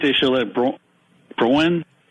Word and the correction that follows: Drouin: how does it pronounce his name? – Drouin. – Starting pitcher. Drouin.